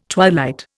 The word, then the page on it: twilight: Indian